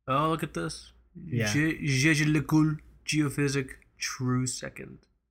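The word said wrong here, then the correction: Jaeger-LeCoultre: It's fucking Jaeger LeCoultre. We just talked about this. Jaeger. – We get made fun of. – Jaeger LeCoultre.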